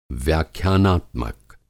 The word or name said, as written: व्याख्यानात्मक